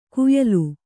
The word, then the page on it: ಕುಯಲು: kuyalu